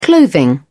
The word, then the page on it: clothing: British English